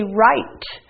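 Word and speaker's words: write: When we say this word, we say right. So the W is silent.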